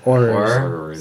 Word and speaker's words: Aurors: Aurors.